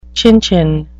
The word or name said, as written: chin-chin